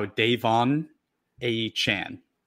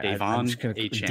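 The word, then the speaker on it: De'Von Achane: So not only did he change the pronunciation of his last name, he changed it of his first name. So you got that wrong too. It's now Davon Achane.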